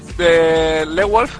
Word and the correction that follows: Lone Wolf: que es para nosotros Lewolf, que es Lewolf Wolf, o algo de eso. – Lone Wolf, sí. – Lone, sí, Lone.